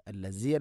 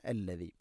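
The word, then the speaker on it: الذي: ഹുവല്ലാഹു അല്ലദീ ഹുവല്ലാഹു അല്ലദീ അല്ലദീ, ദാലിൻ്റെ ഉച്ചാരണം ശ്രദ്ധിക്കുക. അല്ലസി അല്ല അല്ലദീ.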